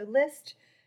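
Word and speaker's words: Lyst: So List, spelled L-Y-S-T, I have to spell it all the time, but List is basically a global fashion search platform.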